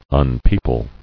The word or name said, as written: Unpeople